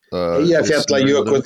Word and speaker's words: Eyjafjallajökull: Is that I'll try to pronounce it, Eyjafjallajökull? – Eyjafjallajökull, yes.